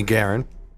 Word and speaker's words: Guerin: And Jamie Guerin.